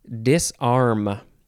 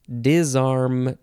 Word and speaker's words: disarm: Disarm. Disarm. Disarm. You can emphasize either syllable and say dis or dis.